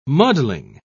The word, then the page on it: muddling: British English